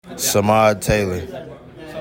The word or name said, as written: Samad Taylor